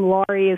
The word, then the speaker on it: Laurie: Lori is spelled L-A-U-R-I-E.